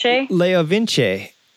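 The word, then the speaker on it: LeoVince: Leo Vince. – Leo Vince. – And if you speak Italian or are familiar with Italian, that makes total sense. It's exactly what it says in Italian.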